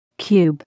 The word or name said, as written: cube